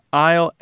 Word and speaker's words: isle: So isle, I-L-E, did indeed come from insula, but isle, A-I-S-L-E, and island, I-S-L-A-N-D, did not come from the Latin word insula, but they added the S anyway. And so they retained the pronunciation as if they didn't have the S, but the newly added S in the spelling persisted.